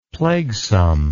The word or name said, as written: plaguesome